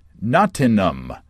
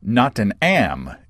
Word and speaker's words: Nottingham: Well, Nick on Twitter says, if you want to feel all cultured, us locals pronounce it Nottingham or Nottingham if you're from the south of England. So Nottingham or Nottingham with a silent H, Nick says.